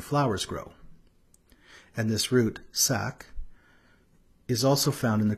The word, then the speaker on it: sak: Now I'd like to draw our attention to the root of these words, sak. It's spelt S with a long A and a K.